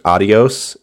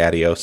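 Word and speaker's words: Adiós: I'm aware that it's pronounced Adios in correct pronunciation, but this game specifically omits the accent, and it's because it's trying to evoke the, like, way that people who don't know how to speak Spanish say Adios sometimes.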